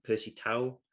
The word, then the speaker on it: Percy Tau: Percy Tau. I'm just pronouncing it as I see it on the paper. – I think that's right, yeah.